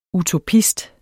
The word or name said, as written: UTOPISTEN